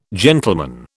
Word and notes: gentleman: British English

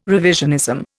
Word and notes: revisionism: Indian

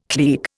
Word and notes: klieg: Indian

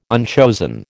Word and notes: unchosen: American English

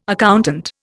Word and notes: accountant: Indian

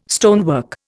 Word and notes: stonework: Indian

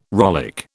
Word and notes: rollick: British English

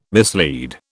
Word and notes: mislead: British English

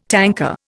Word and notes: tanker: Indian

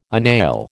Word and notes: agnail: American English

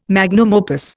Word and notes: magnum opus: Indian

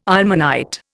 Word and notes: ilmenite: Indian

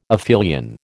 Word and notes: aphelion: American English

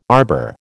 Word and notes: arbour: American English

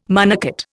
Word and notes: monocot: Indian

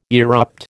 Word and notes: erupt: American English

